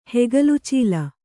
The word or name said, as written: ಹೆಗಲುಚೀಲ